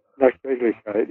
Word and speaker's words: Nachträglichkeit: Nachträglichkeit, I think. – Nachträglichkeit, yeah, right, yeah.